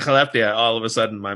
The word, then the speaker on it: Skellefteå: One in Skellefteå.